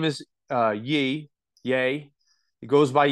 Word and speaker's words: Ye: So Ye, who is a billionaire, by the way. – I would pronounce that Ye.